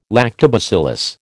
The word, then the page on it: lactobacillus: American English